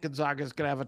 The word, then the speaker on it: Gonzaga: It's Gonzaga, not Gonzaga.